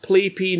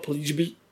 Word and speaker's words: Pleijpje: That's PLEIJPJE, pronounced Playpy, not Pleasby, which is how I call him, and you can get the details there, ask questions.